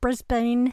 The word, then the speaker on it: Brisbane: She lives with her family on the semi-rural outskirts of Brisbane, Australia. Did I pronounce that right? – That's right.